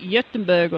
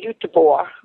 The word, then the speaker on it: Göteborg: It really depends on what language you're speaking when you say the name of that city, but I think the Swedes would say Göteborg.